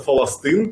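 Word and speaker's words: фаластын (فلسطين): Слово «Палестина» у них тоже нет, у них это называется «фаластын», поэтому я их называю «фалестинцами».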